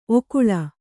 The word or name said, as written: ಓಕೊಳ್ಳು